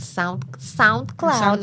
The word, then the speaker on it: SoundCloud: Ah, los pasados en SoundCloud.